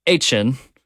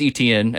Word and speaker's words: Etienne: They brought in Travis, and I'm going to struggle with his name this year, now that we're pronouncing this differently, Etienne, or Travis Etienne, as you probably know him as.